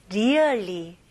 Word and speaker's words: really: 근데 발음을 really, really? 이렇게 하시면 안 됩니다. 네, 첫 번째. R 발음은 일단은 천장을 가면 안 되고 혀가 뒤로 가는 느낌이 나야 됩니다. 그래서 really, 네, 되게 버터 발음이죠. really, really, okay? 그리고 여기에 E 발음은 조금 더 길게. 그래서 really 이게 아니고 really, really, 네, 뭔가 고무줄 당기는 느낌입니다.